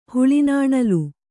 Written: ಹುಳಿನಾಣಲು

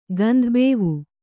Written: ಗಂಧಬೇವು

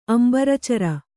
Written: ಅಂಬರೇಚರ

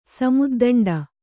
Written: ಸಮುದ್ದಂಡ

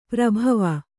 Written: ಪರಿಭಾವ

